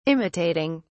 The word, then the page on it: imitating: British English